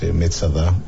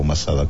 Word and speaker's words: Masada: Uno de ellos cerca de Belén, llamado Herodión, y el lugar al pie del cual nos encontramos, pues Metzada o Masada, como lo pronuncias en castellano.